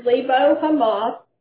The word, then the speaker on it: Lebo Hamath: So it's Lebo Hamath and the Sea of Arabah. So was anybody able to find Lebo Hamath or Hamath on a map? – I could not.